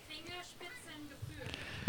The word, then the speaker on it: Fingerspitzengefühl: How do you say it? – Fingerspitzengefühl. – All right, so more of a soft S and a long U.